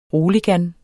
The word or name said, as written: roligan